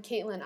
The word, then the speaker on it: Caitlin: I'm Caitlin IR Foster and Caitlin is spelled C A I T L I N.